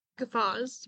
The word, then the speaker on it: guffaws: Hamish guffoffs. Guffoffs. – Oh. – Guffoffs. – I think. Guffaws. – Guffaws.